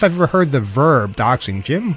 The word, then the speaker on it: doxing: Okay, so anyway, the bulletin says law enforcement at risk for harassment and identity theft through doxing. Did I pronounce that right? Doxing. – Yeah. – D-O-X-I-N-G.